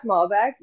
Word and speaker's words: Malbec: So we have a particular wine, which I'm going to butcher the way to say this, and you're going to laugh. Malbec. Is it Malbec? – Yeah, Malbec, either way. Yeah, you did it.